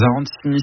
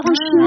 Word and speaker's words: arancini: Ah, les arancini? Ouais. Les arancini? – Bah, c'est un C ou c'est un tche? – C'est un tche. En italien, je crois que le C se prononce tche.